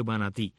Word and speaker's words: Manatí: tienen la última sílaba, pues termina con una I acentuada, con palabra aguda. Y justamente está el pueblo de Manatí.